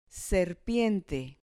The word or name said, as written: serpiente